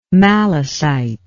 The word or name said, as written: Malecite